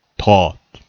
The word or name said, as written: taught